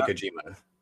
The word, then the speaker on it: Kojima: We got John Moxley going against, it's Kojima, right? – Yeah. Satoshi Kojima.